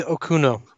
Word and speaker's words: Okuno: And finally, number four, in a 2022 interview, Chloe Okunau, how do you pronounce that? Is it? – I believe it's Chloe Okuno.